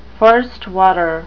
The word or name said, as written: first water